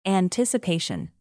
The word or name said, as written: anticipation